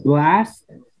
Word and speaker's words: Blxst: His name is Blast. Okay, so it's spelled B-L-X-S-T.